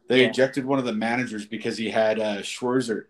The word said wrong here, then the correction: Scherzer: – how do you say his name? – Max Scherzer. – Yeah. Scherzer, yeah. They had him checked like three times in like two innings.